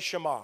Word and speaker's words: Shema: And it's this thing called Shema. Say Shema. Shema. One more time. Say Shema. Shema. Say it like you speak Hebrew, okay? Like you're fluent. Shema.